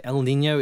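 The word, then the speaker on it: El Niño: El Nino. Are we saying it correctly? – Yes, that's right. El Nino.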